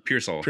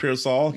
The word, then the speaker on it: Pearsall: How did you say his last name? Parcell? – Pearsall. – Pearsall.